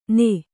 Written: ಣ

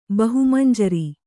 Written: ಬಹುಮಂಜರಿ

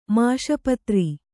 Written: ಮಾಷಪತ್ರಿ

